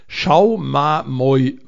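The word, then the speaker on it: Schaumamoi: Schaumamoy Verlag. Und eigentlich so geschrieben, wie man es aussprechen würde. Moy mit M-O-I.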